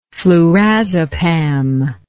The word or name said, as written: flurazepam